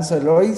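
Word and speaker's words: Sowloy: Iván Soloy, solo que mi apellido se escribe s o W l o y Y pues estoy ahí en Instagram, en Facebook.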